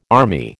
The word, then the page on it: army: American English